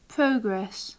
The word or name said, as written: progress